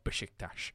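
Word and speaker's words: Beşiktaş: I love saying the name Besiktas because it makes me sound like Sean Connery, even though that is the correct pronunciation of Besiktas.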